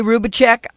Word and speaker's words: Rubacek: We've got Kay Rubacek on the live line. I'm saying your name right, aren't I, Kay? – You're saying it perfectly.